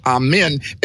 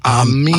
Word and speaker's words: Amen: A-M-E-I-N. And it is not pronounced Amen. It's pronounced Amin. – Amin, yeah. – Ah, like ah in ah. Amin.